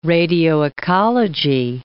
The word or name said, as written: radioecology